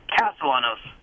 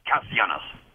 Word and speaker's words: Castellanos: And my last name is the same as Mr. Two Home Runs, but and it is pronounced Castellanos, not Castellanos, in my family.